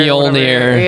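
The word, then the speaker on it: Mjölnir: Mjolnir. – Mjolnir.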